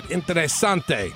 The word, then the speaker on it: interesante: Dos Equis, keep it interesante.